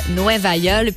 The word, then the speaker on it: Nueva Yol: Ça s'appelle Nueva Yol. Puis c'est prononcé d'une façon très phonétique, typiquement portoricaine, parce que à la base, ça veut dire New York. Mais New York, en espagnol, c'est Nueva York.